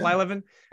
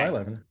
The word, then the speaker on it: Blyleven: But Bert Blyleven, is that how you pronounce the last name? Yeah. Blyleven. Okay. – Blyleven.